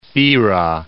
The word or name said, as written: Thira